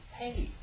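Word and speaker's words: hey (ἡ): Nominative singular feminine is ho. It is hey.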